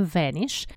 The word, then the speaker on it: Vanish: Тут нам, конечно же, нужно с вами остановиться и вспомнить пятновыводитель Vanish, который называется по-русски, на самом деле произносим мы его со звуком «э», Vanish, который значит «исчезать».